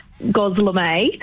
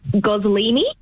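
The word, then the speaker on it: gözleme: Gozlemay. – See, I think that's correct. I have always called it Gozlemay. – Right. – But I have also heard a few people call it Goslini.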